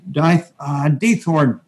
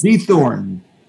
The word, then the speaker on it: Diethorn: And we also have Marne Diethorn. Marne, did I say that correctly? – Diethorn, yes. – Diethorn,